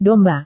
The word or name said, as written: domba